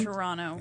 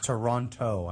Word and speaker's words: Toronto: In Toronto. – In Toronto. Mm-hmm. – That's pretty cool. – I thought Canadians don't pronounce the second T.